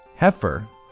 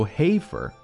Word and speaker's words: heifer: Heifer，注意不要读 heifer， no，这里 e 是没有发音的，所以读 heifer，小母牛。